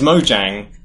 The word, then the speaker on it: Mojang: You can pronounce it either way. – That's interesting. – But some people are very, very hardcore. This is Mojang. – Oh, really? – And you know, wow. Some people pronounce it Mojang.